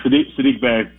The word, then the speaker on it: Sadiq Bey: Is it Sadiq Bey? Am I saying his name right? – Yeah, Sadiq Bey.